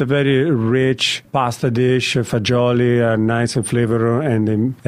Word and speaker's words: fagioli: Pasta fagioli, nice. – Is it fagioli? – Fagioli, exactly.